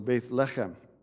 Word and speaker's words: Lechem: Um, so, and, and Lechem, uh, which is the last little part of, or, or Lechem actually, it's, it's supposed to be the emphasis is on the first syllable when it's got two E's on it. So Beth Lechem.